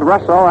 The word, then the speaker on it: Russo: Name is spelled R-U-S-S-O, and as Marius himself says, he wishes it called Russell and not Russo.